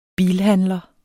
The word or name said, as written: bilhandler